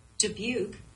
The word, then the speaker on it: Dubuque: and it said Dabuki. And I thought that was wild. – Maybe we've been mispronouncing it entire time. Julian Dabuki. – Well, my Siri says it right here. Hang on. – Dubuque.